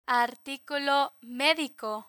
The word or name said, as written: articulo medico